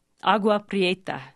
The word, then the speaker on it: Agua Prieta: Agua Agua Prieta.